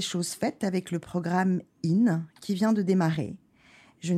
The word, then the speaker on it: IN: vous le prononcez très bien, tout ça se dit in. il y a une petite coquetterie parce qu'il y a deux i, donc voilà, mais ça se prononce in.